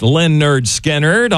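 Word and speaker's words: Lynyrd Skynyrd: Hey, we've got some more tracks from pronounced Leonard Skinnerd on the way for Max Prime Cuts at Noon.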